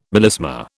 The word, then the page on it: melisma: British English